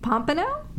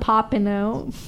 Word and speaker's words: Pompano: Pompano? Yeah, that's how you pronounce it.